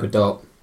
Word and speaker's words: Gadot: Gal Gadot. Is that how you pronounce it? – It's Gal Gadot. – Okay. You say Gadot. I say Gadot.